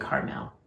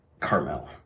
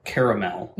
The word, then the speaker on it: Carmel: There's no A in it, so I'm saying Carmel. – Carmel, right. It's not caramel.